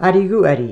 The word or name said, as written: AIRIGH GHUAIDHRE